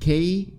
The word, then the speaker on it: Kesagake: Tell me if I'm pronouncing this right. Kesagaki? That was the name of it. Kesagake. – Yeah. – Kesagake, the Japanese brown bear.